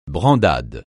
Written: brandade

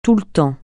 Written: tout le temps

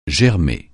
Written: germer

